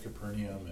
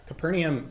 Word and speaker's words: Capernaum: And then one of the questions was, why is Capernaum pronounced that way? Just the spelling looks, it's A-U-M, and I'm just curious about the... – I don't know. – The video said Capernaum. And yeah, Capernaum, that's how I would pronounce it.